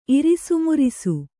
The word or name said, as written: ಇರಿಸುಮುರಿಸು